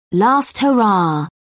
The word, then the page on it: last hurrah: British English